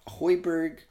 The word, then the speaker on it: Højbjerg: Generally similar, but Højbjerg, sorry, that's how you say it.